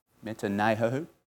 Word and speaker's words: metanoeo: Metanoeo. Is that how you say it? Metanoeo. It's the Greek for repent.